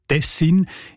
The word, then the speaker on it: Tessin: Die Variante Tessin ist häufiger. Die ist im Schweizerdeutschen Wörterbuch «Idiotikon» belegt im Kanton Bern, in der ganzen nördlichen Schweiz, aber auch im Innerschweizer Kanton Schweiz und das Siselen und das Unterschächen im Kanton Uri. Und auch im Hochdeutschen sieht man Tessin. Die Variante mit der Betonung auf den ersten Silben und mit kurzem «i», also Tessin, ist seltener.